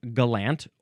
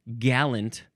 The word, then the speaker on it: gallant: The third form is pronounced gallant or gallant. None of this gallant pronunciation, none of that nonsense. We are emphasizing the second syllable here.